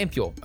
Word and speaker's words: ampule: Yeah, ampule. Okay, that is how you say it.